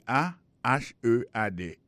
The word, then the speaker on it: ahead: Et head là c'est A H E A D. Ahead. Ahead. Go ahead. Là encore, on a vu que accent tonique là tombé sur deuxième syllabe.